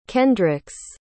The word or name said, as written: kendrix